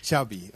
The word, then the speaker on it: Xavi: Xavi. – Okay. Yeah, Xavi like chocolate.